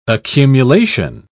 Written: accumulation

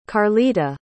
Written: karlita

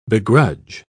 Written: begrudge

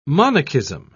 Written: monachism

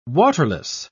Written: waterless

